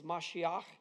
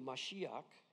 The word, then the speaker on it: Mashiach: The Hebrew word for anointed is Mashiach, which sounds not surprising like Messiah, especially when we pronounce it like we do, Mashiach.